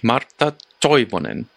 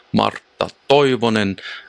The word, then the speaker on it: Martha Toivonen: Ich habe eine gewisse Expertise, aber Jonas sagt ihn eigentlich richtig. Martha Toivonen. Also, beziehungsweise, wenn man es deutsch ausspricht, wenn man es quasi im Originalakzent aussprechen würde, wäre es eher so Martha Toivonen.